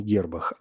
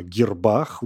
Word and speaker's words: гербах: Это один из самых старых орнаментов, используемых, как я уже сказал, в э, гербах. И если вы сейчас вздрогнули, то поступили правильно, потому что, конечно, не гербах, а гербах. Ударение ставится на букву «А» в этом слове.